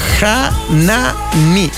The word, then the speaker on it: Hanami: Se llama Hanami, que se pronuncia algo así como Hanami.